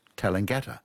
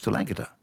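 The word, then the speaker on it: Tallangatta: Well, when I came back to live in Australia, I thought it was Talangatta in Victoria. And of course, it's not, it's Talangatta.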